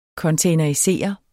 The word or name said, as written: containerisere